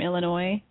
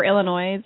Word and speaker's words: Illinois: Who pronounces the S in Illinois? – You know, even I know that, and I'm a bad speller, and I'm not good at saying stuff sometimes. – It's like something you learn, like, in the third grade. You know, like, yeah. – Not Illinois. – Yeah. – It's Illinois. – The Illinois, the S is pronounced oi. – S for silence.